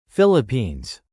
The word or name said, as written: Philippines